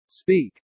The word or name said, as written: speak